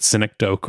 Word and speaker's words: synecdoche: Synecdoche is how to pronounce that one.